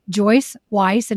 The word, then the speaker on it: Joyce Weiss: Joyce Weiss, and it's spelled Weiss.